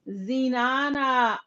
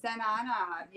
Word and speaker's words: Zenana: Zenana. – I love Zenana. the way that Zenana.